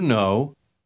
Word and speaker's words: know: No pronuncie la letra K en la última palabra. – Do you know?